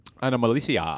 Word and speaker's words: Anomalisa: Anomalisa? – Anomalisa.